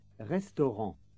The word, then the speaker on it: restaurant: This time, pay particular attention to the letter combination A-N. It is pronounced en, as in restaurant. This is a sound which doesn't exist in English. It's usually described as a nasal vowel sound because it is pronounced through the nose.